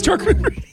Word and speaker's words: charcuterie: It's charcuterie. The way you actually pronounce it.